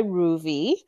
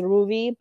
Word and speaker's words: Ruvi: And even Ruvi is not correct. Like, that's not the right way to say my name. It's Ruvi, but there's something I'm doing with my tongue that Canadians can't do.